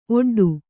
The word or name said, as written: ಒಡ್ಡು